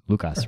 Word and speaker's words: Lu Cas: but it's Lucas, right? – You nailed it, Lucas.